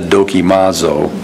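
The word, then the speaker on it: Dokimazo (δοκιμάζω): I don't know, I'm sure that if I was in Greece and I said dokimazo, nobody would have a clue what I just said. But that's how I read it into English. And it's D-O-K-I-M-A-Z-O. Dokimazo is how I would pronounce that in English.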